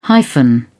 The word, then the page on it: hyphen: British English